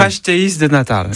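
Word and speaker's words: pastéis de nata: Pasteis de Natal.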